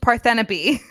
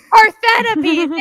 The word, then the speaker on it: Parthenope: A woman named Parthenope. – Parthenope, thank you.